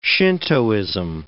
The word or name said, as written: Shintoism